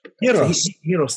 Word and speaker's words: gyros: Hieros, it's spelled gyros on the gyros. – Hieros.